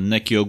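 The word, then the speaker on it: Nneka: E, wejście Smoka e, Neki tak się to wymawia tak, to nazwisko? Ne neka ogólnika, tak.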